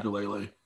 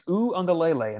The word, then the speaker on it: Uiagalelei: Is that how you say it? I thought it was yeah. Ugalele. – It's it's Uiagalelei, and I know that only because I watched a YouTube video on how to pronounce it a little bit earlier.